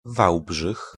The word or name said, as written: Walbrzych